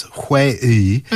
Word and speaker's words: 회의: So it's 회의.